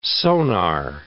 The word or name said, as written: sonar